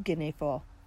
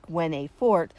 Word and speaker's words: Guinefort: The French pronunciation of the saint's name is Ginefra. Though it's spelled G-U-I-N-E-F-O-R-T, many Americanize it as Gwen-A-Fort, but we're going to use the original pronunciation.